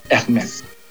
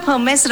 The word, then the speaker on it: Hermes: Hermes. – Hermes. A lot of people say that, but actually it's Hermes. – Yeah, it's like you clean up Hermes right now. – Okay. So H is usually silent.